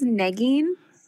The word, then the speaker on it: Nagin: My name is Nagin. – Nagin. – Which rhymes, I like to tell people that it's like legging or begging, you know, it rhymes with those words. So it's like begging for negging, if that helps you.